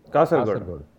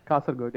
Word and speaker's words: Kasaragod: Fuck, am I pronouncing that right? Kasaragod, right? – Kasaragod. – Kasaragod, yeah.